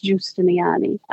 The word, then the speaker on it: Giustiniani: So I pronounce it like his grandmother would say it, which is Giustiniani.